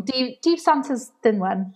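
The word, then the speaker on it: Dydd Santes Dwynwen: I did, I was doing an illustration the other day for Santa's Dinwen Day. I've said that wrong. Deep Santa's Dinwen. Kind of glad Emily's not here to correct my Welsh right now.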